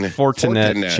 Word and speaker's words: Fortinet: Fortinet. I don't know. I just butchered their name. Do you know? – No, you're fine. – Fortinet Championship.